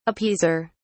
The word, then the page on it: appeaser: British English